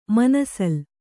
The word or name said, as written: ಮನಸೋಲ್